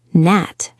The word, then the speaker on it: Gnat: Gnat has a silent G.